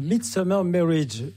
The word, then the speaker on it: The Midsummer Marriage: Alors, je voudrais juste corriger quelque chose. Oui. C'est The Midsummer Marriage. J'ai mal prononcé tout à l'heure.